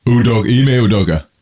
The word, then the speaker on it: Ime Udoka: So how do you pronounce the coach's name? I don't want to butcher it. – Ime Udoka.